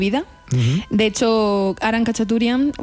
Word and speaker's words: Khachaturian: Se llama Aram Khachaturian.